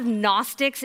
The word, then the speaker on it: Gnostic: You see, there were lots of Gnostics in Colossae. And Gnostic, it's a big word and it's got a silent G, which makes it confusing.